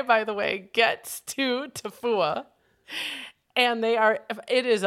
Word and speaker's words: Tofua: Tofua.